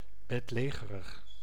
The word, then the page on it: letto: /'lɛt.to/